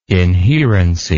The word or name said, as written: inherency